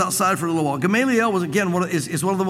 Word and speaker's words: Gamaliel: Then one in the council stood up, a Pharisee by the name of Gamaliel, it's pronounced different ways, I hear, but Gamaliel,